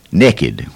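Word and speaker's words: nekkid: We change that word and we say naked, which is spelled N-E-K-K by God I-D.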